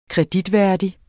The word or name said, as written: kreditværdig